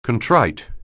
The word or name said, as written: contrite